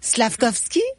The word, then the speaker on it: Slafkovský: On ne prononce pas le F, hein? – Ah, vas-y. – Comment? – Vas-y. – C'est quoi comme le F? – Vas-y. – Ben, moi, je dirais Slafkovský.